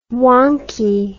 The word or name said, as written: wankie